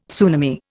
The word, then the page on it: tsunami: Indian